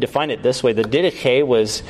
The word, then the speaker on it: Didache: You might have wanted to pronounce it Didache, but it's not Didache. It's Didache. It's from the Greek term teaching, okay?